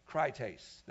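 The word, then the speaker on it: krites (κριτής): Krites. Not important to know how you spell that. Just know it's pronounced krites.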